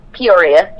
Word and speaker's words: Peoria: Peoria, yes.